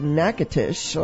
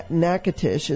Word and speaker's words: Natchitoches: December is, it's, I guess you pronounce it actually, Natchitoches. Okay. I did it wrong. – Natchitoches. – Natchitoches is what it says.